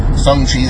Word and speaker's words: Shang-Chi: I think it's pronounced Shang-Chi. That's how they're saying it. Kevin Feige himself, that's how he's saying it.